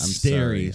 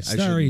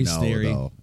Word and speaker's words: Sterry: I thought it was Starry or something, but... – No, it's Sterry. Starry, Sterry.